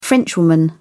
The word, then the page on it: frenchwoman: British English